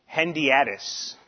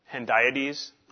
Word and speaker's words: hendiadys: Protestant interpreters say that the two distinct words are a hendiadys. Hendi, Hendi, how do you say that? Hendiadys.